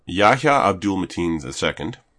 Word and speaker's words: Yahya Abdul-Mateen II: Yahya Abdul-Mateen II.